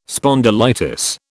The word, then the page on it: spondylitis: British English